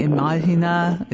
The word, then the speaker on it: Imagina: And then this song, if I pronounce it wrong, just say Imagina, is it? – Yes.